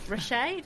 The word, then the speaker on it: reishi: And I'm sure lots of Googling of chronotypes and reishi mushrooms. Reishi? Did I pronounce reishi. – Reishi? Reishi.